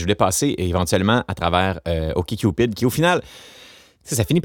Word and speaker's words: OkCupid: OkCupid, c'est comme, bon, je vais bien le prononcer, là, OK, Cupid comme d'accord, Cupid comme Cupidon.